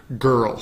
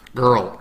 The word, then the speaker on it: girl: So, girl, same thing with that R sound. Abre esos labios, aparta los dientes de arriba y abajo un poquito y girl, it's like a pirate sound, right?